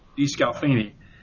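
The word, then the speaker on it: DeSclafani: And DeScalvo, I'm sorry, I'm mispronouncing his name now, Anthony DeScalfani.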